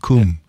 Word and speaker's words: come: The people who are from the Midlands and farther north in England, they don't say come with that O sound. It's like a U sound, cum.